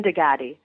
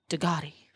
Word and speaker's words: Digaty: Jen Digaty. – Digaty. – I was close. – Ah. Oh my goodness. – You were so close. – It was close. It's like the supercar Bugatti. Digaty.